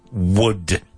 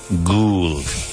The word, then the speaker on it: would: Many Spanish students struggle to pronounce the word wood. W-O-U-L-D. You say something like gould.